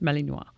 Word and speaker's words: Malinois: Everybody speaks French in Belgium. That's probably why it's pronounced Malinois.